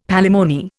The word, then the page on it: palimony: Indian